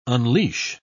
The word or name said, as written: unleash